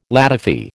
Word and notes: ladyfy: American English

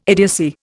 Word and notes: idiocy: Indian

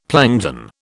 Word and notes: plankton: British English

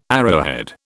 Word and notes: arrowhead: British English

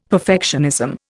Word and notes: perfectionism: Indian

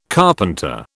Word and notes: carpenter: British English

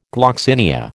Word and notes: gloxinia: American English

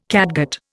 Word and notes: catgut: Indian

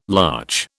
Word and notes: larch: British English

